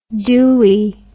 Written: dooie

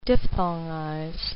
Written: diphthongize

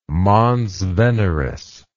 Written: mons veneris

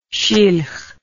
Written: shilh

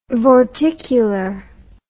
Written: vorticular

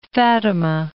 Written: Fatimah

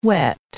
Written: whet

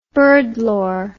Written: birdlore

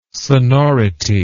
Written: sonority

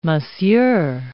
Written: Monsieur

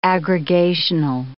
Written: aggregational

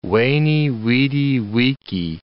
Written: veni, vidi, vici

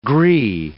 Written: gree

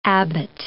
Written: abbot

